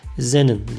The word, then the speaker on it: xenon: Xenon diye yazılıyor. Tabii ben bunları böyle okuyorum ama mesela bunların okunuşu Zenon diye mesela okunuyor.